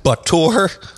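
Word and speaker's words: Batur: I did see the Bentley Mulliner Batour. Is that how they pronounce that? – Batour. – It's got the word bat for today's show. Batmobile. Batour. – Yes.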